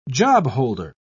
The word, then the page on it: jobholder: British English